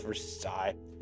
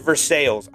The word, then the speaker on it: Versailles: But for some reason, the city I live in Pittsburgh, there's a little area called North Versailles. They pronounce it Versailles. I'm telling you right now, it is Versailles. It makes me cringe every time I hear Versailles. Anyway, moving on.